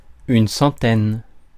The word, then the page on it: centaine: France; [sɑ̃.tɛn]